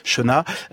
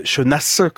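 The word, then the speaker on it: Chénas: On prononce pas le S, Chenat, Chenasse, comme on dit dans le sud.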